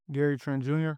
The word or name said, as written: Gary Trent Jr